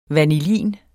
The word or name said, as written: vanillin